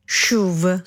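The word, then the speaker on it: CHUV: Sentono una SH ma scrivono una CH e non sanno bene dove mettere le mani, quindi a rigore se lo pronunci come lo pronunciamo noi, quindi SHUV, dovresti dire lo SHUV, che sarebbe la forma corretta, inizia con un suono SH e la regola varrebbe.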